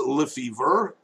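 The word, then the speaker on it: Lefebvre: My first name is Andre, A-N-D-R-E-I. – Mm-hmm. – Last name is Lefevre. It's spelled L-E. F like Foxtrot, E. B like Bravo. V like Victor, R-E.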